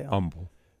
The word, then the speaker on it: Humble: Humble, how do you say Humble.